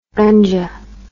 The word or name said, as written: gunja